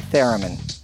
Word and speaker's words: theremin: Didgeridoo, and of course I love the theremin.